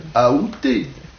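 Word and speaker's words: aoûté: La prononciation qui est considérée comme correcte, c'est la prononciation avec A. Aouté.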